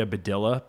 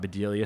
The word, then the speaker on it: Bedelia: And I used to say Amelia Bedilla because I thought Bedelia sounded girly and I didn't want to sound girly up there. So I used to deliberately say it wrong.